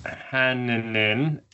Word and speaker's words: Hanninen: yeah, it's pronounced Hanninen. Hanninen. In, in in Finland, the it's the a with dots in Finland, but yeah. – Is kind of just dragged out a little bit.